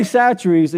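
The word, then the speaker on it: Chachere's: Now, I'm not trying to sell stuff, but Tony Satcheri's is spelled C-H, not Chachery's, for those of y'all from Lulu and Lake City or Fort White. Louisiana, it spells one way, but you say it another, okay?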